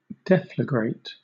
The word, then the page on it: deflagrate: British English